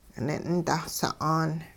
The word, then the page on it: nin'-dahsa'aan: hupa